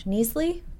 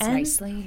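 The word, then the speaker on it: Knisley: It's called Go to Sleep, I Miss You, Cartoons from the Fog of New Parenthood by Lucy, oh gosh, Neasley? Is it a silent K? – I think it's Nicely.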